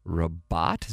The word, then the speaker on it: Rabat: And her father, Albert, was born in Rabat. Is that how you pronounce it? – That sounds right.